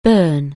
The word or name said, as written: Bern